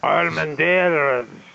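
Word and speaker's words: Armendáriz: Armendariz. You're all the R. – So we got that. – Like pirate. Just pronounce it like a pirate, Harry.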